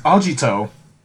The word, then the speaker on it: Agito: Next time we come to you, we'll be talking about Kamen Rider Agitator. – No, you said it wrong. It's Agito.